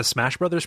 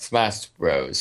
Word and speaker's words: Smash Bros: I call that the Smash Brothers problem, but I'm adult, and also I've never played 8-player Smash Brothers. – Also, it's pronounced Smash Bros, so yeah.